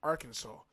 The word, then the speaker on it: Arkansas: And the shit really is pronounced Arkansas. But how? Like, look how it's spelled. And then look how they want you to say it. It's spelled Arkansas, but the shit is pronounced Arkansas. – How? – Because I guess certain. – I'm listening. – I guess certain words. – Certain words. – I guess certain letters. Yeah, I guess certain letters is silent.